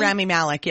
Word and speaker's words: Rami Malek: Rami Malek, yeah, Rami.